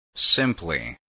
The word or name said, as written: simply